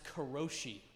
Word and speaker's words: karoshi: It's pronounced karoshi, and it means death by work, or literally, overwork death.